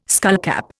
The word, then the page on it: skullcap: Indian